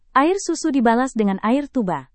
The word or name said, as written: air susu dibalas dengan air tuba